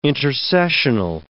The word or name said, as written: intercessional